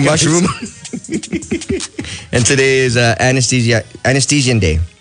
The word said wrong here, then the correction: aesthetician: Did I say that right? Yeah. – Yeah. No, it's aesthetician.